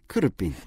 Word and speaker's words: could have been: 크루핀 네, 그래서 이 have 발음이요. h 사운드를 너무 열심히 안 내셔도 돼요. 약간 of처럼 들리기도 하거든요. 그래서 크루핀크루핀 조금 빠르게 가시면 되세요.